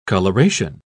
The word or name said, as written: coloration